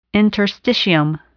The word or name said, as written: interstitium